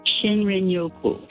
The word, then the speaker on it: Shinrin-yoku: I think it's called shin-yoku. Is that how you pronounce it? The Japanese term for wood air bathing. – Shinrin-yoku.